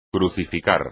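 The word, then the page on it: crucificar: español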